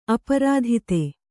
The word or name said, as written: ಅಪ್ರತೀತ